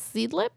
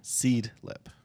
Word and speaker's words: Seedlip: It's so this brand is Seedlip. – Can you spell it? – S-E-E-D-L-I-P. Yeah. – Seedlip.